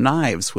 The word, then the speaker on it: Nyves: Time to close up the antidote with another track from Knives, which is spelled N-Y-V-E-S with Fall Behind.